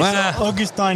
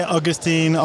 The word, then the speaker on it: Augustin: It's Augustin in French, but it's hard to say in English. So you can say Augustin or even Gus.